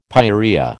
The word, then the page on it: pyorrhoea: American English